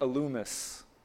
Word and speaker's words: Elymas: The word Elymas is actually pronounced in the Greek Elumus, and it means a wise man.